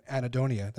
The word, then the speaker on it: anhedonia: I remember a recent one on anhedonia.